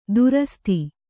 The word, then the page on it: ದುರಸ್ತಿ: durasti